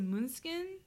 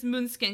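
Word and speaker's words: Måneskin: The band is called, I think it's pronounced like, I want to say Moonskin. It's like M and then like a fancy A with a little hat.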